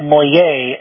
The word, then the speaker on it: Moye: You folks there in the Dallas area, I believe his name is Mole, M-O-L-E, is that right? – It's Judge Eric Moye, M-O-Y-E. – M-O-Y-E, okay, L-E.